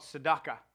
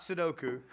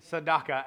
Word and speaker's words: tzedakah: It's called tzedakah. Not Sudoku, Sadaka, and it's actually spelled T-Z-E-D-A-K-A-H, Sadaka, and I think I'm pronouncing it pretty close.